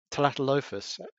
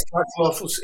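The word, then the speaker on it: Tlatolophus: How do you pronounce the name of the actual genus? It's Talatolophus. Talatolophus? – Yes, Talatolophus is like a kind of a, he uses kind of a mixture of Aztec and kind of a Latin.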